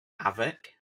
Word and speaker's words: Avec: Avec. – Avec. – Yeah. – Avec. Yeah, I thought it was Ava. I thought the emphasis would be on the E, like the Avec, but Avec.